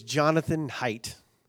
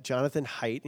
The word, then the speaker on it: Jonathan Haidt: And the researcher's name is Jonathan Haidt. Not the way you would think it's spelled, but Jonathan Haidt.